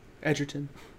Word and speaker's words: Egerton: I thought, how do you pronounce Taron's last name? – Egerton. – Egerton? – Yeah.